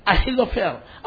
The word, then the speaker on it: Ahithophel: Can you say Ahithophel? – Ahithophel. – It's sometimes very hard to pronounce it. But it is Ahithophel.